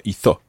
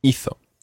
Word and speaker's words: hizo: Watch the third person there. It's hizo or hizo. – Hizo. – It's not hizo. Okay, it's different from some of the other verbs because remember with hablar, we said habló and put the stress on habló. But with hizo, the stress is on the first syllable.